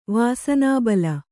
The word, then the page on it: ವಾಸನಾಬಲ: vāsanābala